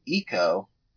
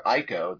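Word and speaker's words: Ico: But Iko was a reference to an old PlayStation 2 video game called Eco, or Iko, depending on how you want to pronounce it, but it was ECO.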